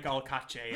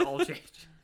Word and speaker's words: Alsace: I hope it's pronounced like Alcache and you're just glowing. – You're trying too hard on the French accent. They're like, no, you stupid American. It's just Alsace.